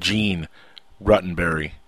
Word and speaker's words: Gene Roddenberry: Even, completely different than probably anything Gene Roddenberry conceived.